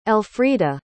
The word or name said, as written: elfrieda